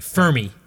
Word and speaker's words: Fermi: Fermi. It's spelled F E R M I. Fermi. Yeah, but he's Italian, so, it's Fermi. Stop saying Fermi.